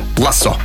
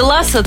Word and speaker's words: лассо: Как нужно произносить? Кухонный, плодоносить, таинство, жерло. – Да-да-да. Внезапно. – Жерло и лассо. Вот так. – Да, если лассо, то это только, как мы уже сказали, знаменитый выдуманный тренер Тед Лассо.